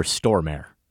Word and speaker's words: Stormare: So you said it closer to what it is than I did, but I've always said Peter Stormare.